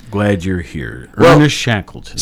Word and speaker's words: Shackleton: Now, let me make sure I'm saying that right. Yes, I think so. Shackleton. S-H-A-C-K-L-E-T-O-N. He was born on this date in 1874.